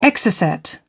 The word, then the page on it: exocet: British English